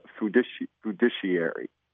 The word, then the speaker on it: fiduciary: I'm learning more about this fiduciary. To be labeled as a, is that, am I pronouncing it right? Fiduciary. – Yep, fiduciary.